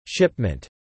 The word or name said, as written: shipment